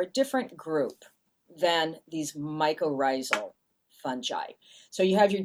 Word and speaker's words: mycorrhizal: There's a phrase that comes up over and over again in the book, and that's mycorrhizal fungi. Am I even saying that correctly? – You sure are. Yeah, mycorrhizal fungi.